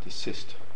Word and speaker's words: desist: Rabbi Eliezer says, from the fact that you have a mitzvah to decease from malacha on Shabbos, Desist. Decease is something else. – No, it's the pronunciation. Again, Americans, desist.